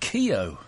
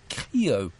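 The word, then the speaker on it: Keogh: A four-piece alternative rock band formed by the Keo, or yeah, Keo Brothers, K-E-O-G-H. I should know how to pronounce that. It's Irish.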